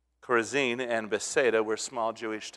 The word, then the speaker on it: Chorazin: And over on the upper corner of the lake, about where Appleton is, that's where Bethsaida and Chorazin, where those cities are, putting the accent on the last syllable because that would be more of a Hebrew way, Chorazin.